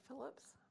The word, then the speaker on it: Phillips: Beverly Phillips. P-H-I-L-L-I-P-S.